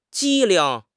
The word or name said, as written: 机灵